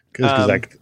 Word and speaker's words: Kurzgesagt: Kurzgesagt.